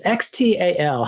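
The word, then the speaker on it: Xtal: This is Aphex Twin, and it's X-T-A-L. How do you pronounce that? – Just X-Tal.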